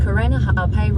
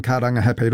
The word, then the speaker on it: Karangahape: That was TVNZ's Māori Issues reporter Tianiwa Huri Hanganui driving around Auckland and unimpressed with the navigation voice on the Google Maps app mispronouncing Karangahape Road and other places with Māori names.